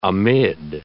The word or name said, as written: amid